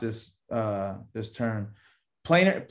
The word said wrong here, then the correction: plantar: plantar fasciitis Plan, plantar fasciitis is where you have a knot on your heel of your foot.